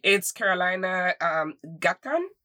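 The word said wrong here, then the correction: Gaitán: Carolina Gaitan.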